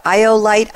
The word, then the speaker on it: Iolite: Iolite, I-O-L-I-T-E 360.